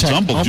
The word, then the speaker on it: Humble: In Humble, don't call it Humble. – It's Humble, it's Humble. Do not pronounce the H.